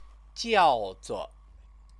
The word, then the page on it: jiaozuo: Chinese